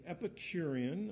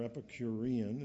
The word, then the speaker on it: Epicurean: The Epicurean, or Epicurean, it's pronounced both ways, and Stoic philosophers were two kind of polar opposite common philosophies in Athens in those days.